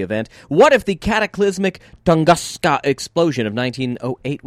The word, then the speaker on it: Tunguska: I believe that is Tunguska.